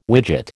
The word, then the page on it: widget: American English